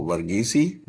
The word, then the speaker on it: Varghese: And my name is Reverend Dr. Chaco Varghese.